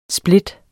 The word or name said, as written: split